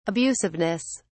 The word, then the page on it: abusiveness: British English